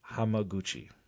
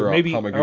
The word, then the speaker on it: Hamaguchi: I think you nailed the last name, Mr. Hamaguchi. – Or maybe not Ryu, but maybe Ryu. Ryusuke. – Ryusuke Hamaguchi.